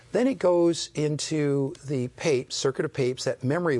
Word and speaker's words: Papez: and it's called the circuit of Papes, even though it's spelled like Papez, P-A-P-E-Z, and that's a memory circuit.